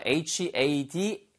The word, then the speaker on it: headache: HEADACHE 에서 This headache is driving me crazy. 이거 발음이 헤드 아체, 헤드 아 이런 거 아니고요. headache. 써 있기는 헤드 아체로 써 있잖아요. headache입니다.